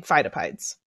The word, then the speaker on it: Phidippides: How is it pronounced? – Phidippides. – You got it.